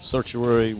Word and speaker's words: certiorari: What's the right way to say it? – It's a writ of certiorari was granted by the Supreme Court.